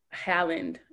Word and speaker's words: Haaland: What does it mean to Native people now that New Mexico representative Deb Haaland, if I'm pronouncing her name correctly, yes, She's now going to be the Secretary of the Interior in the Biden-Harris cabinet? – Mm-hmm.